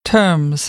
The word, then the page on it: terms: American English; ɝː